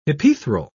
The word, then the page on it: hypaethral: British English